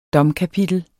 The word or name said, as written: domkapitel